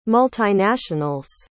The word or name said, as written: multinationals